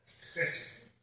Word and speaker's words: хесед: Я не знаю, может, я не так правильно его произношу, но милость, хасед, как это называется, хасед. Вот хосед. – Хесед. – Хесед, да?